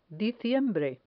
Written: diciembre

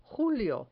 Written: julio

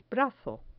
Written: brazo

